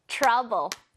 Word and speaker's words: trouble: 여러분, 이거 발음하실 때 트러블 하지 마세요. 자, 트러블 아니고 트 발음할 때 약간 츠 이렇게 합니다. trouble.